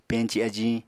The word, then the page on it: peñci ajiñ: Apatani